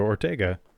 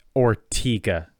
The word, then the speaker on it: Ortega: Well, there's a peninsula in Jacksonville that's spelled O-R-T-E-G-A, which, because of the singer, I would say Ortega, like mm-hmm. Fernando Ortega. But nope, it's Ortega. Everybody calls it Ortega.